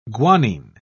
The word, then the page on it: guanine: British English